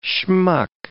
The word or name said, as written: schmuck